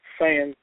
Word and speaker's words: Saiyan: Saiyan, I'm pronouncing it right? – English, yeah, Saiyan. That's what they use.